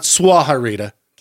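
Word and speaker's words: Sahuarita: Let's try that again. Suharita, Arizona. It's not Suharita. – Okay. – Suharita, Arizona. – Okay, good.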